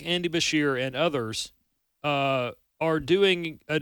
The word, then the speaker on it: Beshear: Andy Beshear. – Beshear, yeah.